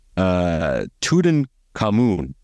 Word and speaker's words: Tutankhamun: And they have coming up, Tutankhamun, that's how you say it, King Tut, his tomb and treasures.